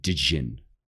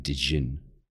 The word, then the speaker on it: djinn: Or the, and it's actually pronounced djinn, right? So it's D apostrophe J-I-N-N, djinn.